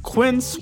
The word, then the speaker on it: Quince: Today Explained support today comes from Quince, which rhymes with since, but is spelt with a Q-U.